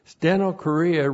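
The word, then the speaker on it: stenochorea (στενοχωρία): This is the preposition and plus the dative plural of steno korea. Steno chorea. And plus the dative, again, dative of sphere, in the sphere of stenochorea. S-T-E-N-O-C-H, long O-R-I-A, stenochorea.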